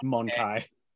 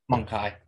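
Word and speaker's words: Mon-keigh: Of note, if you want the official pronunciation on that one, by the way, it's Monkai. – Monkai, okay. – Monkai. – It's intentionally written to sound like the Eldar calling humans monkeys.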